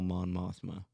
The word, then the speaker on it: Mon Mothma: Well, that is true, because, like, the political side was, like, all Mon Mothma. – Yeah. – And that is how you say her name, right? – Yeah.